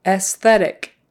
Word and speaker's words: aesthetic: Just pretend that first A isn't there. Aesthetic. Aesthetic. I like the aesthetics of this. Aesthetic. Aesthetic. So you do pronounce that S after the E, and it's an S sound, not a Z. S, and then TH, th-th-thetic. Aesthetic. So STH can be tricky. S-th-s-th-s-th. Aesthetic.